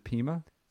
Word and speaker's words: Pima: And that's, you know, it's Peruvian Pima cotton. Did I pronounce that right? – Yeah. – Is it Pima? – Soft, right, soft shit.